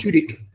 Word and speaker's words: Tudyk: It's a really good, really good show starring Alan Tudyk. Is that how you say his name? – Alan Tudyk, I think it was. – Tudyk.